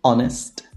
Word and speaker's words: honest: There are only four words in which the H is silent. And obviously, the, the words that are either compound or, or that come from these words. And they are our, honor, honest, air.